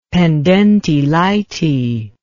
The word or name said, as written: pendente lite